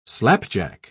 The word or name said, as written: slapjack